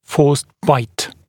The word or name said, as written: forced bite